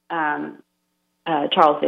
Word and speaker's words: Charles: And they all decided to pray through the intercession of Charles de Foucauld, or in French, Charles is pronounced Charles.